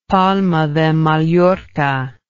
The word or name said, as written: palma de mallorca